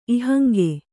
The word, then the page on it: ಇಹಂಗೆ: Kannada